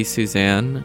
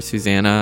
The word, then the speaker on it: Suzanne: And of course, that song had to be Suzanne, or Susanna. I guess you kind of pronounce the E at the end.